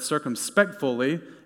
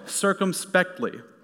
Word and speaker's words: circumspectly: I actually pronounced it wrong for a long time. I said circumspectfully until my dear wife today told me I've been pronouncing it wrong for years.